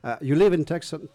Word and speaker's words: Tucson: Tucson, c'est ça, Tucson. – Ils disent Tucson. – Tucson. – Tu prononces pas le C.